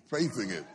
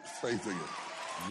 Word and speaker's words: Faithing It: Faithing It. Faithing It. – Yeah, rhymes with faking it.